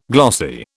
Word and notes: glossy: British English

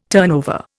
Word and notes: turnover: Indian

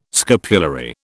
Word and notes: scapulary: British English

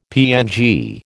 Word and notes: PNG: American English

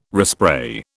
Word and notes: respray: British English

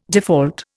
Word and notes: default: Indian

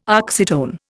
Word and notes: oxytone: Indian

